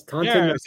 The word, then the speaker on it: Taunton: Taunton? Am I saying it right? – You sound like you're from Taunton. Yes, Taunton.